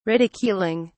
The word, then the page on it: ridiculing: British English